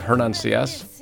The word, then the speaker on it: Hernan Sias: Today I'm looking to have Hernan Sias.